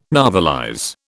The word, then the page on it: novelize: British English